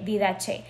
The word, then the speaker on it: Didache: Hay un libro o un manuscrito muy, muy, muy, muy, muy antiguo que se llama La Didache. Se escribe Didache, así, C-H-E, Didache.